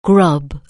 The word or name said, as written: grub